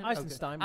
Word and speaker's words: Eisenstein: Eisenstein.